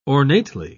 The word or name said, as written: ornately